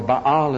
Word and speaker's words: Baal: You say that Baal is the one that lives, or Baal as it should be pronounced.